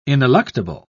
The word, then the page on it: ineluctable: British English